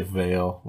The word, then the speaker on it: veil: So this tradition of the whale. Is that the right pronunciation? My Swedish mind. – Well, we say veil with the V.